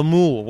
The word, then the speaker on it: moule: First, we were a bit picky in the start because we wanted la moule. What is it? Mussels. Is that how you say it? – Yeah, we wanted moule frites.